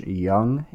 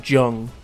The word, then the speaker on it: Jung: maybe, depends on how you look at it, versus Josh Jung. It's not a J, it's pronounced with a Y. Or it's not pronounced as a J, it's pronounced as a Y, I'm pretty sure.